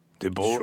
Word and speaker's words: Debord: I think it's Guy, but you don't pronounce the final D, right? It'd be Guy Debord. Debord? – Sure.